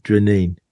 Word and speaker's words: Dreaneen: DREANEEN.